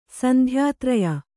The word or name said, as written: ಸಂಧ್ಯಾತ್ರಯ